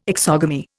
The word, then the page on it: exogamy: Indian